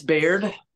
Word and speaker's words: Baird: It's Baird.